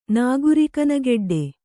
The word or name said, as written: ನಾಗುರಿಕನಗೆಡ್ಡೆ